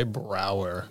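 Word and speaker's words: Braugher: Andre Brauker. – Oh, yeah. – I'm not sure entirely how to pronounce the last name. – Who's that? – Captain Holt. – Captain Holt. – Andre Brauer. – Thank you.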